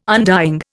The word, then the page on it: undying: Indian